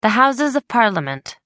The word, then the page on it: Houses of Parliament: American English